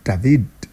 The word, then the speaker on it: David: Yes, I'm calling it David because the guy is Spanish and you have to pronounce it the Spanish way. David.